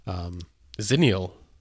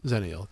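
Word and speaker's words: Xenial: And then Canonical surprised everybody by announcing that their most recent release, which I can't pronounce. – Xeniel. – Yes, Xeniel. That sounds good.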